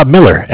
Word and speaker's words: Miller: My name is Zach, spelled Z-A-C-H. And thank you. Last name, please? – Miller, M-I-L-L-E-R.